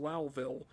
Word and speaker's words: Lowville: It's known as Lowville, spelled Lowville, but it's pronounced Lowville.